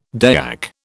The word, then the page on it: Dayak: British English